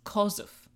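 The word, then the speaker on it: Chorzów: So, and I did my own pronunciation looking up there, and I was pretty proud of myself because it's spelled Chorzow, but it's pronounced Kosów.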